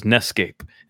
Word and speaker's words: NEScape: So this is NEScape, and it rhymes with escape.